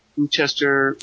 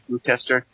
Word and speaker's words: Gloucester: Gloucester, Marblehead. Oh, sorry, Gloucester, Marblehead. – He still mispronounces it.